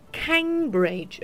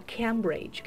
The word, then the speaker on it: Cambridge: 这边注意一下哈，如果说单独念这个呃剑桥的话，啊是叫做 Cambridge，而不是叫 Cambridge，可以感受到这个发音区别吗？是 K。 A bridge，OK，Cambridge，Cambridge，啊，如果说是 a 的话，就变成 Cambridge 了，这是不对的，应该是 Cambridge 啊，这个发音注意一下。